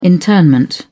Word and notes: internment: British English

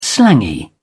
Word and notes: slangy: British English

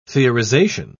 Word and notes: theorization: British English

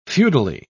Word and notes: feudally: British English

